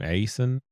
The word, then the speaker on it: Aison: The writer, Patrick Aison, I think is how you say his last name, Aison, A-I-S-O-N.